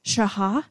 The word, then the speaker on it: shachah: And so I looked up one of the Hebrew words for worship, which is most used, is shaha. It's spelled S-H-A-C-H-A-H, but it means to bow down or to prostrate oneself.